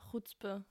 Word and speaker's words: Chutzpe: Und zwar hat uns der Christian das Wort Chutzpe, habe ich das richtig ausgesprochen? Ja, Chutzpe. Chutzpe.